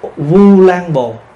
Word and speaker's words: Vu Lan Bồn: Đúng ra là Ulambana hay là Ulambồn, nhưng mà mình đọc trại ra thành Vu. Chữ Vu bây giờ là thế chữ Ô đó, Ulam thì mình đọc là Vu Lan Bồn.